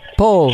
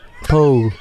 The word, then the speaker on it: Paul: Paul. – Can you spell it for me? – P as in Peter, A as in Atom, U and L as in Lucy. Paul.